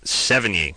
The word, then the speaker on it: Sevigny: And how do you say Chloe's last name? – Chloe Sevigny. – Sevigny. – Yeah.